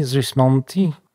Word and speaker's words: Gismonti: Jak się wymawia Gismonti? Gismonti? – Gismonti.